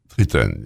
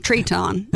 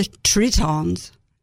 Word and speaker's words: Treton: How do you say it? – Triton, yes. – Triton. I say it. I'm Arkansan. Triton. But it's Triton.